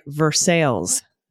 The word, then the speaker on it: Versailles: My great grandmother lived in Versailles, like Versailles. not the French pronunciation, Indiana, which is also home to a giant pumpkin fest where folks grow pumpkins that are hundreds of pounds. Anyway, back on topic, not at all pronounced with the French pronunciation, but the most basic English way possible.